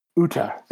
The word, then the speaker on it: Uta: Am I saying her name right? Am I even saying her name in the ballpark? I don't remember. The main character is this girl who... Uta. There we go.